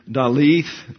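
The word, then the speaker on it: Dalet: The Hebrew alphabet's fourth letter is the Dalith or the D. And it looks like a seven if you note your notes there. That is a D and it's pronounced as an English D.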